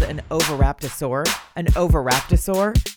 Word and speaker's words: Oviraptor: I always want to put saurus at the end, so I have found that I say it wrong, as though I'm going to complete the word with saurus, but I don't. It's just Oviraptor.